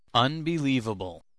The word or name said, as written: unbelievable